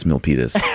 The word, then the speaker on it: Milpitas: That's not how you pronounce Milpitas? – That is how you pronounce Milpitas.